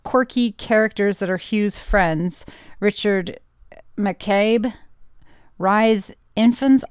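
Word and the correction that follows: Rhys Ifans: I don't know how you say his name. R-H-Y-S? – Reese Ifans. – Okay, that one.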